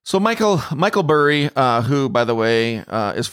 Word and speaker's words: Burry: Am I saying that right? Is it Burry? – Yeah, Michael Burry.